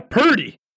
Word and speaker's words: Purdy: Purdy? – Yeah, Purdy. Spell that. – P-U-R-D-Y? – Yes!